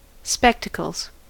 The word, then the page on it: spectacles: American English